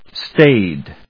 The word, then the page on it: staid: /stéɪd/